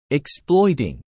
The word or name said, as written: exploiting